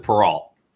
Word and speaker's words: Perreault: So I pronounce it Perot. Okay. So everyone wants to pronounce it the French way, but you know what? I'm American. So all right. All right. – I pronounce the T.